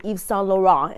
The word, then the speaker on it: Yves Saint Laurent: I'll stop saying YSL to get out of saying Yves Saint Laurent.